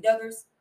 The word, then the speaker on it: Duggars: The Duggars.